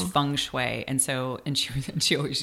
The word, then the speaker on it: Feng Shui: And she also was like, I used to call it Feng Shui, and she's like, no, it's Feng Shui.